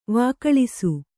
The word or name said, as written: ವಿಕಳಿಸು